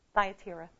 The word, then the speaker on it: Thyatira: Am I saying that correctly? Thyatira. Okay, I'll take it.